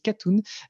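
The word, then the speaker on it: Katon: Euh, je parle de Mathieu Katoun alors ça s'écrit Kato n euh, mais ça se dit Katoun euh, qui est le kiné qui, m'accompagne à Castres.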